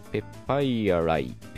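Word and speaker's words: papyri: スクライバルっていう、筆者のっていうか書いたもののっていう形容詞はスクリバルじゃなくてスクライバルと読んだり、これも難しい単語ですね、パピルスの複数形ペパイアライは、パピリと読めそうだけど、ペパイアライと発音されていました。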